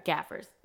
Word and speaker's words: gaffers: And even when I think about her crew for the shot, it's chock full of like Chicago Gap, or I always say this name wrong, G-A-F-F-E-R, gaffers.